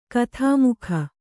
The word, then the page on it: ಕಥಾಮುಖ: kathāmukha